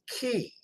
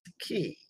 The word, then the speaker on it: Cay: Interestingly enough, C-A-Y for most Bahamian islands is Key. It's pronounced Key. But Coco Cay is pronounced K.